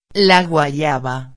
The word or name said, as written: la guayaba